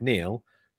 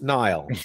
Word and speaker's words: Niall: And although it's spelt Niall, it's Neil. It's just the fact that he's Scottish and that's how they spell it. And they're weird up there. But it is just Neil.